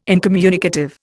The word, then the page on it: incommunicative: Indian